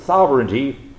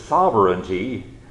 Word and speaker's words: sovereignty: Well, my formidable task for this next half hour is to take this four-syllable word, sovereignty, or for those of us who are Southerns, we might say three syllables, sovereignty, and help us to understand what it is and explain how sovereignty is different from omnipotence.